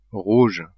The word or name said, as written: rouge